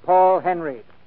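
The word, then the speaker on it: Paul Henreid: He pronounces his name Paul Henry.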